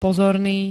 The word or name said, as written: pozorný